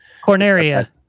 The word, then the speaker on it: Corneria: You're going in to do the stuff, and it makes you feel that, you know, like you said, the first level of Corina or whatever, however you pronounce that? – Corneria. – Yeah, Corneria.